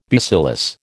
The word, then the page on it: bacillus: American English